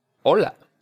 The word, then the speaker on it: hola: Remember that O is just a simple O. Don't drag it out into an O, like an English O. Hola. O. – Hola.